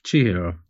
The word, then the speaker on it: Chihiro: Chihiro Suzuki. Did I say that right? Chihiro? – Yes, you did. – Is it Chihiro or Chihiro? – Chihiro.